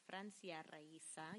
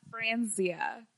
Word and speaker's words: Francia Raisa: Okay, so social media, you can follow me on Twitter, at The Real Francia, on Instagram, at Francia Raisa, or if you want to know how to say it right, it's Francia Raisa. – Yeah. Not Francia.